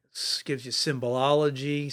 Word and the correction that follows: symbology: Is that right? Did I say that right? Symbology? – Yeah, symbology, I guess.